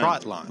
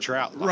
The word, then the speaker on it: trot line: Like T-R-O-T? – I guess. It's not trout. – Okay. – But I've always heard it pronounced trout line. – Right, yeah, I have too. – So that just, I was just like, wow, I've been wrong all this time.